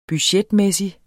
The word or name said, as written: budgetmæssig